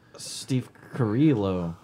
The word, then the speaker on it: Steve Carrillo: Can we let pronounce it, please? – Steve Carrillo. – There we go.